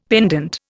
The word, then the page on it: pendent: Indian